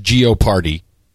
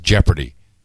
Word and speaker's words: Jeopardy: For those of you who do not live in Gitmo Nation, United States, the game show Jeopardy, most Europeans pronounce it as Geoparty. But it is actually pronounced Jeopardy.